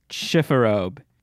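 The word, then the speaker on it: chifforobe: I think it's like C-H-I-F. It's French, so I think I'm completely wrong on this. – Chiffarobe. Oh, like robe, like R-O-B-E.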